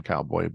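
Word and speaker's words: Cao Boi: Which features a character named Cowboy, which is spelled differently than like the American cowboy. But the fact that his name is pronounced Cowboy is fantastic.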